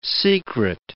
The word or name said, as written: secret